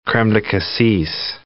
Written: creme de cassis

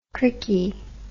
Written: crickey

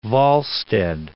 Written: Volstead

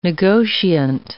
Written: negotiant

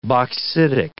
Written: bauxitic

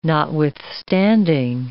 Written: notwithstanding

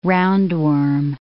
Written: roundworm

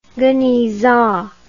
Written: genizah

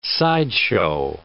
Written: sideshow

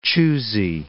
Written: choosy

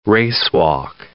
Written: racewalk

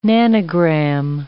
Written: nanogram

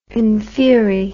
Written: infieri

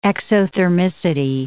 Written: exothermicity

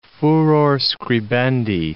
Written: furor scribendi

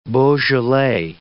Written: Beaujolais

